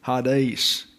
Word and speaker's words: Hades: or Hades. The E there is an eta, so it's pronounced as a long A. Hades is the way it's pronounced, and it's accented.